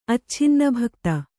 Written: ಅಚ್ಛಿನ್ನಭಕ್ತ

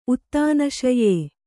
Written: ಉತ್ತಾನಶಯ

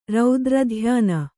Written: ರೌದ್ರಧ್ಯಾನ

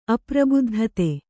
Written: ಅಪ್ರಬುದ್ಧತೆ

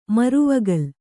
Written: ಮರವಗಲ್

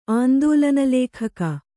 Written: ಆಂದೋಲನಲೇಖಕ

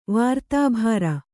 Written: ವಾರ್ತಾಭಾರ

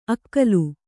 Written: ಅಕ್ಕುಲ

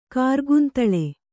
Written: ಕಾರ್ಗುಂತಳ

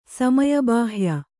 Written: ಸಮಯಬಾಹ್ಯ

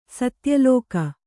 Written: ಸತ್ಯಲೋಕ

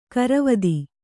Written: ಗೊರವಿತಿ

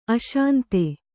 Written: ಅಶಾಂತ

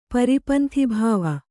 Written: ಪರಿಪಂಥಿಭಾವ

